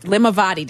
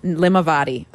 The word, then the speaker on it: Limavady: Limavady. Did I say it right, Eric? – Nailed it. – Limavady.